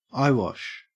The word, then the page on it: eyewash: Australian English